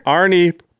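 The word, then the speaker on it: Arni: It's like Arnold Schwarzenegger. – Arnie Broken Brow, yeah.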